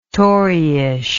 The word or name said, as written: toryish